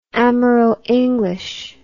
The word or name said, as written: amero english